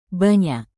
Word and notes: banyak: indonesian

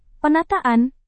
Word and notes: penataan: Indonesian

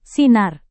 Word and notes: sinar: Indonesian

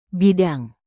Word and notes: bidang: indonesian

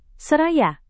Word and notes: seraya: indonesian

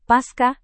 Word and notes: pasca: Indonesian

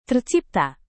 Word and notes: tercipta: indonesian

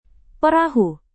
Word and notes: perahu: Indonesian

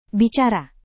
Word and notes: bicara: indonesian

